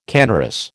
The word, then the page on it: canorous: American English